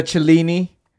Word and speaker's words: Cellini: The Cellini. That's how you pronounce it, by the way.